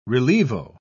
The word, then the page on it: relievo: British English